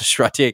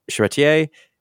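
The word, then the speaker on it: Charretier: And again, we've said this before, you know, I don't know where Elsa Chartier came from. That was a terrible pronunciation. Chartier.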